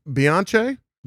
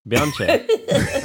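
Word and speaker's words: Beyoncé: Sure, it's pronounced Beyonce. – Beyonce.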